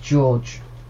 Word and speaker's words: Gorg: Well, apparently the surname's pronounced George.